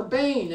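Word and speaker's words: bane (בן): And so the word son here in verse 12 is bar, not ben, or bane, as it's correctly pronounced.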